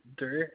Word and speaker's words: Derr: My first name is William, and my last name is Durr, and that's spelled D-E-R-R.